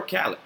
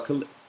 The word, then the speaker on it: Khalid: I hope Cardi wins, either Cardi or 21 or Khaled. Khalid. I'm going to call him Khaled. Wow, because I just have DJ Khaled in my head. It's wild, because every time I see Khalid, I see DJ Khaled.